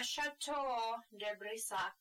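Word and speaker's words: Château de Brissac: So, The green lady is a ghost that roam halls at, I'm gonna butcher this one, the Chateau de Brissac in French. – That was good.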